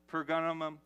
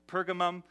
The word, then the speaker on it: Pergamum: Ephesus, Smyrna, Pergamum. I looked up how to say it earlier today, and I butchered it, but Pergamum, Thyatira, Sardis, Philadelphia, Laodicea.